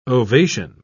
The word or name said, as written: ovation